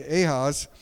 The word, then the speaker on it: Ahaz: And so Isaiah, acting as God's prophet in this time, is urging Ahaz, if I'm saying it right, Ahaz, to trust the Lord rather than political alliances.